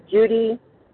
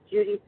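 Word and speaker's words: Judi: This is Judy J. Judy spelled with an I, from North Central Illinois.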